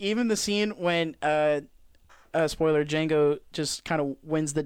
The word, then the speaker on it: Django: Dude, and then when the dude that's dying on the floor, he says Django, and he goes like, the D is silent hillbilly, and it just blows his head off.